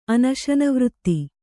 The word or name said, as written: ಅನಶನವ್ರತಿ